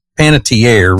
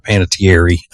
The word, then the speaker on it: Panettiere: It's Panettiere, Riggins told me, not Panettiere. I've always pronounced it wrong, I guess.